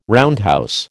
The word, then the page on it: roundhouse: American English